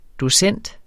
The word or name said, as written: docent